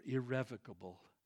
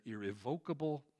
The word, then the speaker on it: irrevocable: God's promises to the patriarchs, and highlight verse 29, for God's gifts and his calling are irrevocable. Some people pronounce that irrevocable. The dictionary accepts either one.